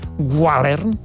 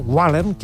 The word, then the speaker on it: gwalern: Ve del bretó, una paraula bretona, que és, no sé com es pronuncia, més o menys, gualern, G-W-A-L-E-R-N, gualern, que vol dir vent del nord-oest.